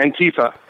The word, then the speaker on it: Antifa: Number two, Antifa. Is this how you pronounce it? – Yeah, Antifa.